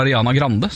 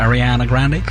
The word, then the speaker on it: Ariana Grande: en amerikansk artist med særnorsk uttale. Vi sier bare Ariana Grande, selv om hun vel egentlig kaller seg Ariana Grande.